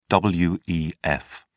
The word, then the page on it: wef: British English